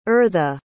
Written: ertha

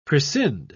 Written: prescind

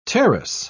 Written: terrace

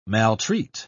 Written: maltreat

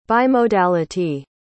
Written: bimodality

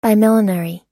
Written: bimillenary

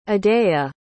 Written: odeya

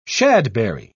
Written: shadberry

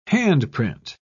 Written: handprint

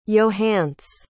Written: yohance

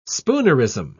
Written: spoonerism

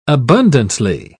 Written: abundantly

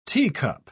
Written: teacup

